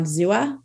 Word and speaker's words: Xooa: So now I'll tell you, we're on Xoa. It's spelled X-O-O-A.